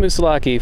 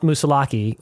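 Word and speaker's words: Moosilauke: Mount Mussolacci, 4,802 feet. Super windy up here. But amazing, amazing views in every direction. – For northbound thru-hikers on the Appalachian Trail, Mount Mussolacci, or Mussolac, as some pronounce it, marks one's entry into the White Mountains.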